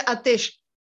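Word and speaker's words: Ateş: Se llama Seiran Ates. Sí, se, pronuncia Atesh, Seira, Seiran Atesh.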